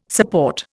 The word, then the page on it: support: Indian